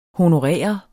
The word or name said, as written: honorere